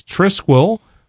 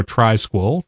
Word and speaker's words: Trisquel: There is Musix, M-U-S-I-X. There is Parabola. There is Trisquel, or Trisquel, depending on how you prefer to pronounce that, T-R-I-S-Q-U-E-L. And there's Ututo, U-T-U-T-O.